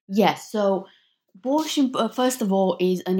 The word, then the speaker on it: borscht: Now, also another big part of your book deals with something called, and I hope I pronounce this correctly, borscht. – Yes. – Borscht.